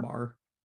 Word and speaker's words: Maher: How do you say Maher? Maher? – Mar. – Mar. Z-O-M-B. Z-O-M-B-A-E.